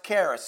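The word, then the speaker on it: charis (χάρις): When Jesus walked through Palestine talking about God's charis, C-H-A-R-I-S, but kind of pronounced with a K, more than that C-H is hard, his hearers knew what he meant.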